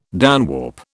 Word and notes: downwarp: British English

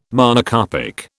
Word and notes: Monocarpic: British English

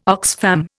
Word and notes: Oxfam: Indian

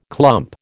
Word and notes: clump: American English

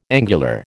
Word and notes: angular: American English

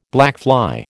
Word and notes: blackfly: American English

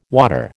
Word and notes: water: American English

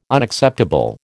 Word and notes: Unacceptable: American English

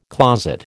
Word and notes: closet: American English

